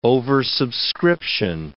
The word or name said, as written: oversubscription